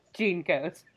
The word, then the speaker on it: JNCO: Well, the boys also wear, in her cohort, wear, especially JT wears, in the next episode, wears those, like, red Junkos. – Jinkos? – Whatever. I never wore them, so I never knew how to say them. – Jinkos. – I'm sorry, there's no vowel between those two letters to tell me how to pronounce it. – Jinkos.